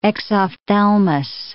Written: exophthalmos

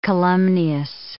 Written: calumnious

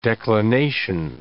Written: declination

